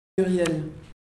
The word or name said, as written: Muriel